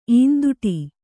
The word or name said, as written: ಈಂದುಟಿ